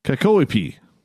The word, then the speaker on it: Kakoipi: Kakoiapi. I butchered that. Kakoipi. There we go.